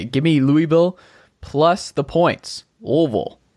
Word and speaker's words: Louisville: Yes, Lu- Louisville. No, it's like I, uh, there's no S. – Like you have to just say Louisville.